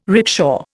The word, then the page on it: rickshaw: Indian